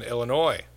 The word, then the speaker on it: Illinois: I know people that pronounce the S in Illinois.